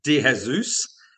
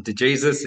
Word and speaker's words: De Jesus: So it's meant to be DeJesus. But in Australia and growing up, it's always just been DeJesus. It's easy and everybody can say it.